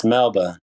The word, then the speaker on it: Melbourne: Did you know there's a town in, like, between Melbourne and Sydney called Wagga Wagga? – It's Melba. – Melbourne? – Melbourne. – Melbourne. Mm-hmm. Yeah, sorry, Australian listeners. I totally butchered the name.